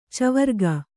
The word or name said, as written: ಚವರಿಗೆ